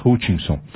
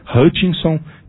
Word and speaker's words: Hutchinson: Nós temos três formas de falar o nome da empresa: Hutchinson, que é a pronúncia americana, Hutchinson, que é a nossa pronúncia aqui, e Hutchinson, que é a pronúncia francesa, né?